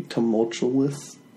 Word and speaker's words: tumultuous: How do you pronounce that? – Tumultuous. – Tumultuous. – I read books and I say tumultuous. – Why was I trying to put an L in there? Tumultuous. – Didn't I put an L? Tumultuous.